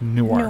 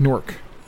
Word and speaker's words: Newark: And if you're from Delaware, Newark, Delaware is pronounced Newark. – Newark. – If you're in New Jersey going to Newark, it's Newark.